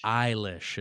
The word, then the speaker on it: Eilish: Eilish is how you pronounce the last name.